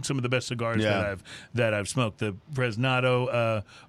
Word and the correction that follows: Prensado: or Prensado. – Prensado. – I always mispronounce that, but that comes to mind.